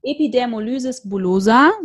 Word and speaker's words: Epidermolysis bullosa: Also, also wir haben schon gehört, du hast Epidermolysis bullosa, so wird es ausgesprochen, ne? – Genau.